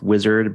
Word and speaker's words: Uizard: And you can look right now at tools like, I think it's pronounced wizard, but spelled U I Z A R D, where you can sort of provide a text prompt and it will create an interface for you.